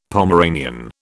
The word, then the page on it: Pomeranian: British English